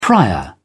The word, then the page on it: prior: British English